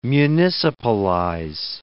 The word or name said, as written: municipalize